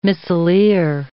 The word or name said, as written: missileer